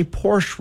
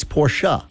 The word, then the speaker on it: Porsche: I say Porsche wrong. It's Porsche.